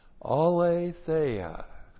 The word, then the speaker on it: Aletheia: A L long E T H E I A. Aletheia.